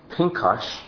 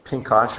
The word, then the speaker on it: Pinchas: Pinchas is how you pronounce his name in Hebrew. Pinchas or Pinchas.